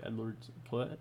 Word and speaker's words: Edwards-Helaire: Sorry, the H is not pronounced right. Correct? – Mm-hmm. That's correct. That's what Clyde I've heard. Edwards-Helaire. – Clyde Edwards-Helaire. Yes.